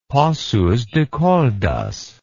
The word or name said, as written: Pocos de Caldas